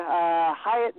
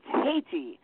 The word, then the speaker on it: Hayti: If you're in the central portion of Missouri, Hyatt, Missouri at Hyatt, or Haiti. It's Haiti. H-A-Y-T-I. That's got to be how it's pronounced. Haiti, not Hyatt.